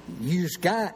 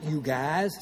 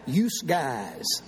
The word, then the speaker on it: yous guys: Yous guys, not you guys. Say it right. Yous guys.